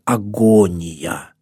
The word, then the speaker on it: агония: Это слово по-гречески «агония». Агония, если правильно ударение ставить.